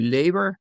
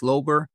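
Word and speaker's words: Löber: Now, this is L-O-U-M-L-A-U-T-B-E-R, and it's pronounced differently. It could be labor, but my wife's branch of the family, it says Lober, and so in deference to her, that's the pronunciation that I'm going to use in this radio broadcast, okay?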